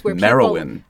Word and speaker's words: Merenyn: Merowyn. – Merowyn. It's spelled like M-E-R-E-N-Y-N, but the author gave me the authoritative pronunciation.